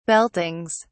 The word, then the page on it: beltings: British English